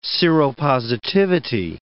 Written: seropositivity

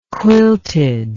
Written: quilted

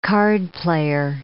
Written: cardplayer

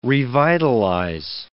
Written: revitalize

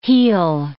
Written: heal

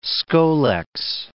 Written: scolex

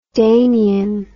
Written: Danian

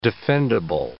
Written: defendable

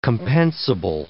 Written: compensable

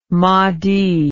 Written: moti

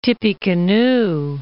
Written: Tippecanoe

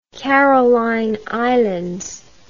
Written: Caroline Islands

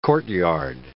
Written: courtyard

